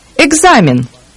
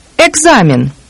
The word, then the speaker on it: Экзамен: Экзамен. Экзамен.